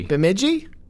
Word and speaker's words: Bemidji: You tell me how this word is pronounced. B-E-M-I-D-J-I. Bemidji? – Bemidji. – Bemidji? We don't know. We apologize. – No, I do. It's Bemidji.